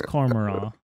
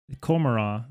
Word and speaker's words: Commorragh: No, it's Commorragh. Oh. Wait, what? Commorragh.